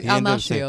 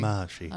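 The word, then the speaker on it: Almásy: Almacy. Is that how we pronounce Ray Fiennes? No, it was a member who was like Almashi or something. – Almashi. – Almashi. – He's Hungarian, remember? – Almashi is given clues by a local...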